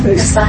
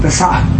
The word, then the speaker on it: Pesach: Pesach. – Pesach.